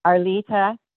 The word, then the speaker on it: Arleta: Arlita Nordgren. – And can you spell that for me? – A-R-L-E-T-A. Nordgren, N-O-R-D-G-R-E-N.